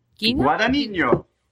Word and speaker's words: Guadagnino: And it's made Luca Guadagnino. – Guadagnino. Hey, I say it like Bill Hader doing an Italian impression.